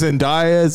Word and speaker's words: Zendaya: Second ago, it's Zendaya.